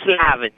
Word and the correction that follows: Levine: you know who that is? – Yeah, Levine, Bruce Levine, the broadcaster. Bruce? Yeah. – I think it was Levine.